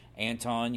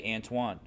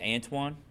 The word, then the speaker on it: Antoine: And his name is spelled weird, and I always wondered how to say it because you hear some people say Anton, you hear some people say Antoine. – Yep. – So you never know, but I guess his name is Antoine? – It is correct. I always pronounced it Anton as well, too, because of how it was spelled.